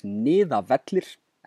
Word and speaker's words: Niðavellir: The band's name is pronounced Skalmöld, the song is Niðavellir, and the album is Vögguvísur Yggdrasils.